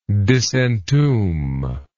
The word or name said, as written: disentombment